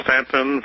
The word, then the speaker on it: Staunton: 126 Madison Place, and that's Stanton, Virginia, 24401. – And Stanton is spelled what now? S-T-A-N-T-O-N? – S-T-A-U-N-T-O-N. The U is silent. – Okay, S-T-A-U-N-T-O-N, oh, yeah.